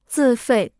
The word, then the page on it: 自费: zì fèi